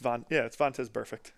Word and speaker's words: Vontaze Burfict: To finish off the episode, we're going to talk about Vontaze Burfict. Am I saying that right? – Close enough. – What is it? – It's Vontaze Burfict.